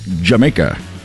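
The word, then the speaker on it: D'yer Mak'er: I know it's spelled like Dyer Maker, but it's pronounced Jamaica.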